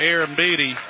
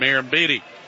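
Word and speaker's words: Arrambide: No chase there from Aaron Beattie. And I had to spell his name phonetically. It's A-R-R-A-M-B-I-D-E.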